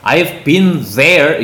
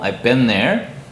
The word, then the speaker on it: been there: I've been there 이렇게 발음하지 마시고요. been 다음에 th가 생략되듯이 하셔갖고 I've been there.